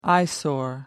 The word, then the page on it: eyesore: American English